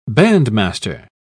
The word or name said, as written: bandmaster